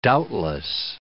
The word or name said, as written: doubtless